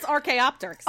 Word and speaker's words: Archaeopteryx: Yes, Archaeopteryx.